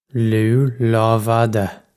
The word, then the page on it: Lugh Lámhfhada: Loo Lawv-oddah